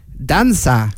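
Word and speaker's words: Dança: Hoe spreek je dat uit? Danka Semparar. – Danza. – Oh, is het een C? – Ja, het is een C. – Een hele rare C met een staartje eronderaan. – Danza Semparar.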